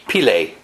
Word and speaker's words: Pillai: Pillai, or he pronounced it Pillay, but I don't think he pronounced it correctly. I think it should be Pillai.